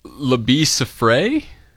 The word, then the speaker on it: Labi Siffre: Labi Siffre? It's spelled L-A-B-I space S-I-F-F-R-E.